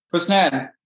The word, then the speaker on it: Husnain: Today we're here with, how do you say your name, Husnain? – Husnain.